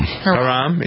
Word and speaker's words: Haram: I say Haram. Who's it? Is it Haram? – Haram. – Haram, is it? – But it sounds fun to make it sound like you're making it rhyme with Procol Harum.